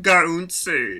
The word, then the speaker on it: Gaunce: So is it Gaunce? Is it Gaunce? I keep going back and forth. I found this really quick clip of how this guy pronounces it. – Cameron Gaunce. Cameron Gaunce.